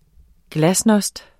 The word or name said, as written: glasnost